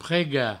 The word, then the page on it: pregar: prega; provençal